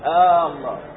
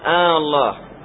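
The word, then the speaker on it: آلله: فنحذف ألفا وتكون ألف واحدة فوقها علامة المد، فوقها علامة المد، تقول الله، تصير مدا هكذا، هكذا نطقها العرب، يقولون الله،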